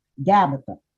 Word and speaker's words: Gabbatha: But in the Hebrew, it is pronounced Gabbatha.